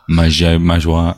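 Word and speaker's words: Mageia: Magaya. – I mean, there is a mage in there, so it's Magaya. It's a bit like Magic, which has the Mandrake connection because it is a community fork of Mandriva.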